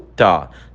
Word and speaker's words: thought: A pronúncia do tó se dá com o TH, com a língua entre os dentes, soltando o ar devagarzinho, como bem parecido com through, tá?